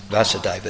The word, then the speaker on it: Vasudeva: Well, Krishna is known as Vasudeva with a long A, isn't it? Yeah, because he's the son of Vasudeva with a short A.